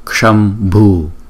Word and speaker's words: Kshambhu: Luôn luôn có chữ k nhưng chúng ta không đọc rõ là chữ k. Chúng ta đừng có đọc là kshambhu, kshut, kshambhu, không? Chỉ đọc là chữ cờ nhỏ nhỏ thôi.